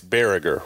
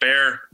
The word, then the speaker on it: Berger: It's Berger, right? – Right. Yeah. Like Berger.